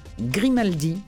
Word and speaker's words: Grimaldi: C'est de Virginie Grimaldi, comme ça se prononce, comme la famille Grimaldi, ou comme Francine.